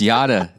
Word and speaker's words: Yada: And that chapter is titled Yada Relationship. Right? – Yes. – Did I say that correctly? – It's Yada. It's Yada Relationship.